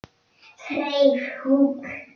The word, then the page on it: writing corner: British English